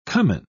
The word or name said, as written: cumin